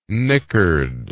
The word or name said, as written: knickered